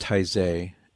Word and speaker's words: Taizé: Well, I've always heard it pronounced Taizé, and although I've never attended one of the Taizé prayer services, I know many people who have.